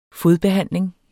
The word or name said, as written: fodbehandling